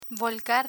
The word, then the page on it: volcar: spanish